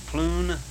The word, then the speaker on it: Pleune: Her name is Catherine Ploon. That's a strange name for me to pronounce, but it's spelled, her last name is P-L-E-U-N-E.